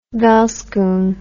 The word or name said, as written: velskoen